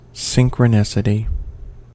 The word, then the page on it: synchronicity: American English